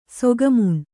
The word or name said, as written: ಸೊಗಮುಣ್